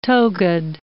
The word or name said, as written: togaed